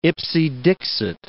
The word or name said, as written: ipse dixit